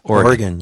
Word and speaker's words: Oregon: That's the correct pronunciation, you know. – Oregon, yes. Now to Oregon.